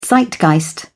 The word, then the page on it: zeitgeist: British English